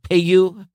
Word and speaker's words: Peugeot: I can never pronounce this car name. The Peugeot, P-E-U-G-E-O-T. Okay. Yeah, Peugeot. I'm sorry. Peugeot.